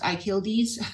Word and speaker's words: Akyildiz: And the first one on the left is Professor Ian Akyildiz. Yes, his last name is pronounced Akyildiz, from the Georgia Institute of Technology.